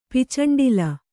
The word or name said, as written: ಪಿಚಂಡಿಲ